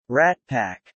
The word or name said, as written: Rat Pack